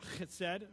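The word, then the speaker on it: chesed (חסד): Is that right, Joel? Is that chesed? Am I right? All right, I got to say it correctly, even though I'm spitting all over the place. But it's chesed, and it summarizes faithfulness and godliness in contrast to the selfish malice and infidelity of the wicked.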